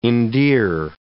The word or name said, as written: endear